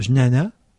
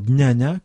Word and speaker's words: jnana: ça vient même d'une origine sanscrite, le jnana, qui se prononce même maintenant jnana, comme la gnose, hein.